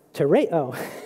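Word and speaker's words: tereo (τηρέω): The Greek word translated keep is tereo. It's pronounced tereo.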